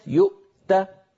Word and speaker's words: يؤت: فننطق همزة ساكنة ثم تاء شديدة ثم مهموسة، ولم يؤت هكذا نقف على هذه الكلمة.